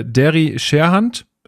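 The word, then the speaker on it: Scherhant: Wie bewertet ihr die Entwicklung und Chancen auf Spielzeit in der kommenden Saison für Derry Sherhand? Habe ich das richtig ausgesprochen? – Das ist richtig.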